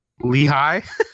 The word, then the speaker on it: Lehigh: Lehigh.